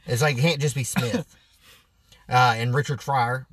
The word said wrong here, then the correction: Fire: Richard Fire. Not Fryer. Fire.